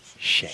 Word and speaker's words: Shad: Chad Moss. – No. – Shad. – Oh, Shad. – It's a soft C. – Oh. Hey, Chad. – It's a soft C. – I'm from the way. You know, it'll be one nigga from the way. You know, it'll be one. You always be. – The soft C is an S.